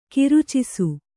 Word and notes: ಕರ್ಚಿಸು: Kannada

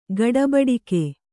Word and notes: ಗಡೆಬಡಿಗ: Kannada

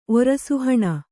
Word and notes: ಒರಸುಹಣ: Kannada

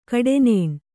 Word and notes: ಕಟ್ಟನ್ನ: kannada

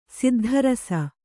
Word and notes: ಸಿದ್ಧರಸ: siddha rasa

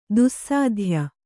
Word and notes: ದುಸ್ಸಾಧ್ಯ: dussādhya

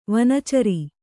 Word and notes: ವನೇಚರಿ: vanēcari

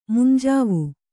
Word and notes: ಮುಂಜಾವು: munjāvu

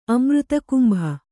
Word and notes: ಅಮೃತಕುಂಭ: Kannada

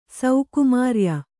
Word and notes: ಸೌಕುಮಾರ್ಯ: saukumārya

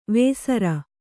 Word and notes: ವೇಸರ: vēsara

